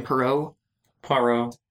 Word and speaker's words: Poirot: Poirot.